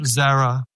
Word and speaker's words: Zara: And I was trying to see, like, Zara, is that how you pronounce Zara? That means break. – Yeah, Zara.